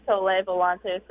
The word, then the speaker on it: Soleil Volante: You said Soleil Volante. Is that the proper pronunciation? Soleil? – Yes. Luca's the Italian, and he says Soleil Volante, so I'm going to go with that.